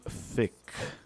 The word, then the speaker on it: Thicke: But Robin Thicke. You know, there's a silent E at that, and I feel like that says it all right there.